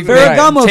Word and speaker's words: Ferragamo: Ferragamo.